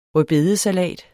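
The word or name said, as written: RØDBEDESALAT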